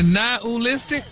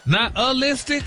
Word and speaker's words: nihilistic: Nihilistic? – Wow. – Stop putting the ooh in there. – Nihilistic? – There you go.